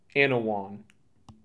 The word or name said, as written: Annawan